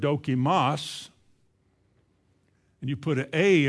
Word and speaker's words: dokimos: The word tried is a Greek word pronounced dokimos. Dokimos.